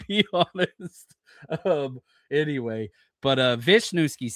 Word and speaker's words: Wisniewski: It's not Wisniewski. It's Wisniewski.